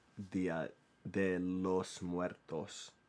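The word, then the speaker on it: Día de los Muertos: So, this the song, the celebration is called Dia de Muertes or Dia de los Muertes, Dia de los Muertos. One more time. Dia de los Muertos.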